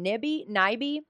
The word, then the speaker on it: Nyby: He was particularly upset when Christian, I forgot to look up the pronunciation. Nyby. It's N-Y-B-Y. Mm. Christian Nyby.